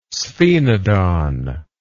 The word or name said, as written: sphenodon